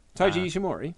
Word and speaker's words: Ishimori: But I really would have liked Ishimori. – Taiji Ishimori? – Yes, okay, yes. Yeah, I did say it right.